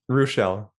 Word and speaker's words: Ruechel: Ruechel.